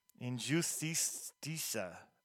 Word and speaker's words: Injustiça: On February 28th, they released Injustica. Let's see if I'm saying that right. – Injustiça. – I didn't say it right.